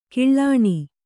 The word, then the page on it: ಕಿಳ್ಳಾಣಿ: kiḷḷāṇi